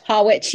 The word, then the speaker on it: Harwich: So that was the Stenaline ferry from, is it Harwich? I never know how to pronounce it. Harwich, yeah, I know what you mean.